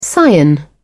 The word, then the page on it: cyan: British English